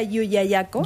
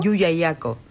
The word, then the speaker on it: Llullaillaco: Sería Llullaillaco. – Llullaillaco.